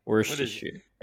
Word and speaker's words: Worcestershire: I think, yeah, I think you like pronounce it like S-H-R at the end, like sure. It's just like... – Yeah, Worcestershire.